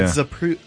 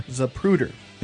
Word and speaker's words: Zapruder: Oh yeah, Zapruder. – Zapruder. – Zapruder.